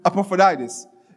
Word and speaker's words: Epaphroditus: Epaphroditus.